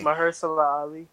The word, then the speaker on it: Mahershala Ali: How do I pronounce this nigga name? – Mahershala Ali. – There we go.